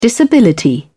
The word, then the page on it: disability: British English